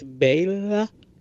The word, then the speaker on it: Bela Lugosi: But I learned something that I want to say off the top, which is that I always thought it was Bela Lugosi, but it's Bela. Bela Lugosi is how you say his name.